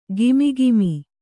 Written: ಘಮಘಮ

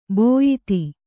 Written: ಬಿಯದಿ

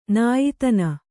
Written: ನಾಯಿತನ

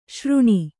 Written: ಶ್ರಾಣ

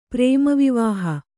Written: ಪ್ರೇಮವಿವಾಹ

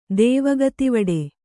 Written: ದೇವಗತಿವಡೆ

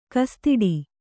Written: ಕಸ್ತಿಡಿ

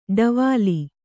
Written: ಡವಾಲಿ

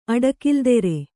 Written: ಅಡಕಿಲ್ದೆರೆ

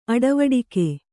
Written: ಅಡವಡಿಕೆ

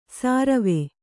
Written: ಸರ್ವೆ

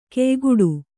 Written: ಕೆಯ್ಗಟ್ಟು

